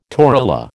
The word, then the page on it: torula: American English